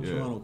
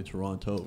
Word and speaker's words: Toronto: But that's what Toronto culture is. It's like knowing those, or not pronouncing the T. We don't say Toronto, right? – I'm bad with it.